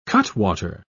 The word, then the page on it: cutwater: British English